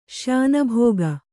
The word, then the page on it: ಶ್ಯಾನುಭೋಗ: śyānughōga